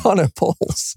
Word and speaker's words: monopoles: Monopoles.